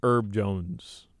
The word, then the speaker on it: Herb Jones: Are you just saying Herb because that's the way you're normally pronouncing H-E-R-B? – I think it's Herb Jones, isn't it? – Well, herb. – I know what an herb is, and it's spelled the same way as herb. I've never heard a herb called herb, though. You know what I mean? The name is Herb. – But if your name is going to be H-E-R-B, then your name is Herb Jones, brother.